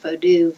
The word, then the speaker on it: Vodou: I would first say for people to forget everything they've ever heard about Vodou, V-O-O-D-O-O. If people notice I'm pronouncing it Vodou, V-O-D-O-U, which is the Haitian Creole spelling.